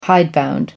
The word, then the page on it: hidebound: American English